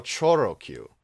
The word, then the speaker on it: Choro Q: Oh, Choro Q. – It's, well, I guess it is Choro. How is it pronounced? Let me see. – I mean, that's how you'd say it in Japanese, so.